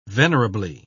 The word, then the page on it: venerably: British English